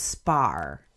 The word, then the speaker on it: Spa: I said he was born in Leamington, Spain, because it was spelled S-P-A, so I assumed it was like, you know, an abbreviation for Spain, but it's pronounced Spar.